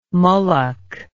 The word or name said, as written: mullock